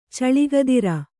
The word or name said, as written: ಚಳಿಗದಿರ